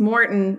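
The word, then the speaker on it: Maurten: Wait, and I just realized I'm saying it wrong. It's Morton because I just met those guys in Boston and I realized I've been saying it wrong this whole time. So it's Morton and it's M-A-U-R-T-E-N.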